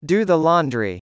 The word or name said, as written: do the laundry